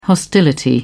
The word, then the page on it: hostility: British English